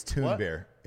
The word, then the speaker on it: Thunberg: Is Greta Thunberg an American citizen? – I hate to correct you. It's Thunberg. It's Thunberg. That's the proper pronunciation.